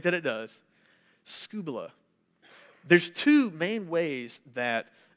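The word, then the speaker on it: scubala (σκύβαλα): Interesting thing about this word is in the Greek, it is pronounced scubala. Can you say that to your neighbor? Scubala. Scubala. Has nothing to do with diving in the ocean.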